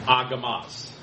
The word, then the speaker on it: agamos: This word unmarried, agamos, was really the standard word in Greek for a male who had lost his wife, for a widower. – What's that word again? – Agamos. Gamos means married. Agamos. – Can you spell it? – A-G-A-M-O-S.